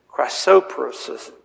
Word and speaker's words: chrysoprasus: The syllable is, the accent is on the second syllable. It's chrysoprasus. Chrysoprasus.